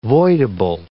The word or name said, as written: voidable